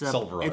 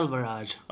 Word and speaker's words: Selvaraj: It's Selvaraj. It's pronounced right Selvaraj.